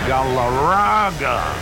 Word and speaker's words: Galarraga: I love that first baseman, Andres Galarraga. You're supposed to roll your R's.